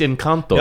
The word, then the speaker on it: Encanto: Is it Encanto or Encanto? – It's Encanto. Yeah. – Shut up. – Proper Spanish pronunciation.